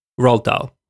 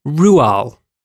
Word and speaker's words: Roald: That's not how you say his name. – What? – No. You want to know how you pronounce his name? – Sure. – Rual.